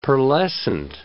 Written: pearlescent

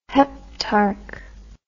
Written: heptarch

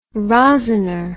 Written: rosiner

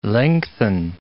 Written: lengthen

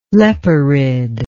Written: leporid

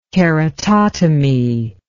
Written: keratotomy

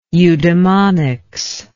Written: eudemonics

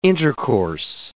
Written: intercourse

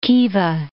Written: Khiva

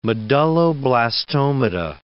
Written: medulloblastomata

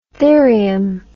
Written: therium

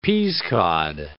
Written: peascod

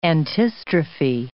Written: antistrophe

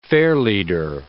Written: fairleader

